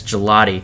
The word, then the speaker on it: Gigliotti: and we were debating the pronunciation of his name because it's spelled Gigliotti, but apparently he insists that it's Gelati.